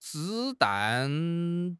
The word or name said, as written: 子弹